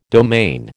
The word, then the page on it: domain: American English